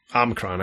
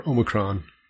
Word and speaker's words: Omicron: Omicron, I can say it right. Or Omicron.